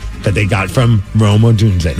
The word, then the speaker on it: Odunze: It's named after rookie Rome Odunze or Odunze? – Odunze. – Odunze, thank you, Odunze.